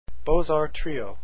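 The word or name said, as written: Beaux Arts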